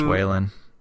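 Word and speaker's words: Weyland: Yeah, like, there's no reason to keep him around if you're the Weiland Corporation. – I think it's pronounced Weylan.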